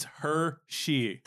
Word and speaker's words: Hershe: No, because it's spelled H-E-R-S-H-E. It's Hershey.